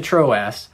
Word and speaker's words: Troas: When I came to Troas to preach the gospel of Christ,